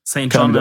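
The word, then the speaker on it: St. Jhn: Und zwar geht es um Roses von St. John oder St. J-H-N. – St. John. Ja. ausgesprochen.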